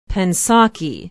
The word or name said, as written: pensaukee